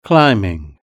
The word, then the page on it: climbing: British English